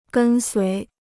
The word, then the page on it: 跟随: gēn suí